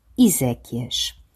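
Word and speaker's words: exéquias: Por outro lado, constitui um dos casos particulares de pronúncia, porque, embora se escreva com a letra X, a sua segunda sílaba lê-se Zé, Iséquias.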